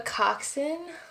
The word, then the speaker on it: coxswain: A coxswain? – Well, oh, that's how you pronounce it? – Yeah, so silent S and W, I guess.